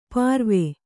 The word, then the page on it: ಪಾರ್ವೆ: pārve